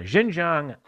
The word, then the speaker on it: Xinjiang: The NBA ran into myriad problems by opening one of the academies in Xinjiang in, in uh, is it pronounced Xinjiang? Yeah, Xinjiang, a police state in western China where more than a million Uyghur Muslims are now, now held in barbed wire camps.